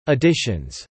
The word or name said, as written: editions